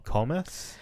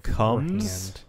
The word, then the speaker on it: Komes: What we have for you today is the Komes? – I mean, probably should have looked up how to pronounce it. – Comes? – The Comes. – Either way, it's spelled K-O-M-E-S.